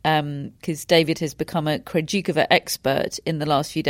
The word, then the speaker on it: Krejčíková: And I think we're going to come on to talk about Barbora Krejčíková. That is how I'm pronouncing her name on the basis of how she pronounces it on the WTA website.